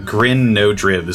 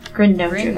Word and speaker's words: Grin Nodriv: Grin Nodriv is what I said. – Grin Nodriv, okay. – How do you spell that? – G-R-I-N? – Grin, like the word grin. – Yeah. – N-O-D-R-I-V.